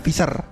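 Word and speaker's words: Pfizer: PV ya bacanya Fisher.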